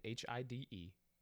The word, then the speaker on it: Hidetaka: Give it your best shot. Hitaki. No. – It's H. It's Hide. Hidetaka. Hide. I'm sure it's not Hidetaka.